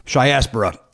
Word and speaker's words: Chiaspora: Anyway, it's spelled C H I A S P O R A, and it's my friend Mark Morgan, and he Shiaspora. Thank you. Say it out loud, please. – Shiaspora, and it's about Chicagoans that have gone on to success.